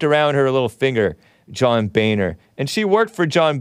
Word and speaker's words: Boehner: According to a LinkedIn profile, worked for John Boehner, spelled Boehner. So some of us thought that it was pronounced differently, but it's pronounced supposedly John Boehner.